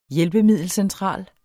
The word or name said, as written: hjælpemiddelcentral